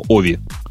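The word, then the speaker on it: Ovi: О, мне представитель Nokia говорит, что ударение на О, OV.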